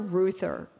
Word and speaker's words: Reuther: Let me just say his name first, Walter Reuther, because it's not a name you hear anymore. Who's that? It's spelled kind of strange, sort of like Reuters, R-E-U-T-H-E-R.